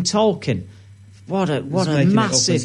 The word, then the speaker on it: Tolkien: We're both pronouncing it differently. – It's Tolkien.